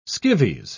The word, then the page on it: skivvies: British English